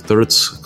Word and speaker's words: TRDS: Э, вкратце TRDS, читается как Thirds, кто знает тот момент, посмеется.